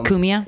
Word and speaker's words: Cumia: Well, I go on Anthony Cumia's show a lot. – Oh, yeah. Cumia? – Yeah, Cumia. – Cumia.